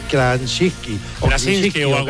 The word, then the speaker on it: Krasinski: El actor sigue siendo el mismo, bueno, el que ha hecho la serie, que es John, a ver si lo digo bien, John Krasinski. – Krasinski, se llama así.